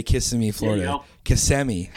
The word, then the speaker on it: Kissimmee: In the city of Kissimmee doing a little landscaping.